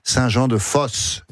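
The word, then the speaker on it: Saint-Jean-de-Fos: Alors, ça s'écrit F-O-S, mais comme vous l'avez dit vous-même, c'est mieux prononcé, je pense, Saint-Jean-de-Fosse.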